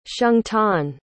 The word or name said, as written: xiangtan